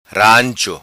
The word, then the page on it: Rancho: patua